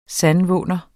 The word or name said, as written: sandvåner